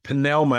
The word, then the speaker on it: pneuma: It's pneuma, and it's in the Greeks, you know, they pronounce the P. So like they would say psychology. We don't say that. We say pneuma as for the word, but it's pneuma.